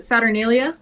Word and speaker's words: Saturnalia: What's it, Saturnalia? – Yeah.